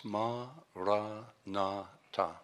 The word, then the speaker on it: Maranatha: If you choose this word, say it as four syllables, ma, ra, na, ta.